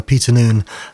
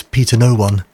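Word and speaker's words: Noone: If I remember rightly, at once, I must try it in the not too distant future, but the screen reader Jaws used to pronounce Peter Noon as Peter No One, which is always found amusing.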